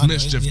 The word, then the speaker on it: MSCHF: It's spelled capital MSCHF, right? – No. – They're also all capital. It's Mischief, though.